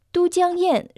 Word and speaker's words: 都江堰: Ирригационная система Дудяньен на китайском языке звучит как Дудяньен 水利工程. Она была создана на реке Минджан в юго-западной провинции Китая Сычуан. Дудян – это древнее название реки Минджан. Оба иероглифа Ду и Тян произносятся в первом тоне.